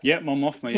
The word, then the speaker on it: Mon Mothma: And of course, the second woman in the movie was, I always say this wrong, Mon Mothma. – Yeah, Mon Mothma, yeah.